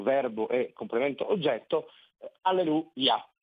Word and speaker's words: alleluia: che correttamente avrebbe l'accento alla fine, per cui alleluia,